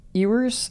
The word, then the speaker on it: Ewers: Ewers. – I'm just going to... – Like a U, like a lamb. – Yeah, I'm going to keep saying it right. – Ewers, yeah.